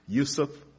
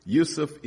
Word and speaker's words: Yusef: Going back to what you just asked me, my name, Yusef Abdul Hakim. Yusef is the given name my mother gave me, which is the Arabic pronunciation for Joseph, which means may God add or increase her.